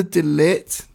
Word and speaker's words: De Ligt: On the De Ligt, did I pronounce that correct? – Aye.